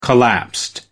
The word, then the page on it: collapsed: American English